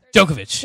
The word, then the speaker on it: Djokovic: Djokovic.